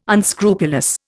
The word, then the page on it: unscrupulous: Indian